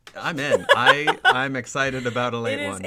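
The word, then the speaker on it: Ale-8-One: Known colloquially as ale ate, pronounced as, a late one. – Oh, a late one.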